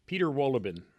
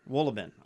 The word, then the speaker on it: Peter Wohlleben: Peter Wohlleben. – Wohlleben.